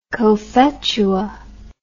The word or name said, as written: cophetua